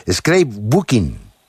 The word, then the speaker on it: scrapbooking: Y un palabra aquí en inglés que yo no sé qué es, scrapbooking. – Scrapbooking, pues ahí lo he dicho bien. – Sí, lo has dicho muy bien, tema, aunque el inglés no sea nuestro fuerte.